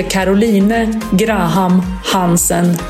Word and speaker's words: Caroline Graham Hansen: You are listening to Their Pitch and this is the Caroline Graham Hansen episode. – Mitt namn är Caroline Graham Hansen. – And that's how you say it in Norwegian.